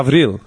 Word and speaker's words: Gavril: It's the Greek pronunciation of Gabriel. – How's that? – Gavril. Right? – Oh, okay.